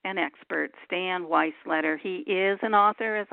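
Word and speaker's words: Weisleder: And also, Stan's last name is spelled W-E-I-S, like Sam, L-E-D-E-R, Weisletter.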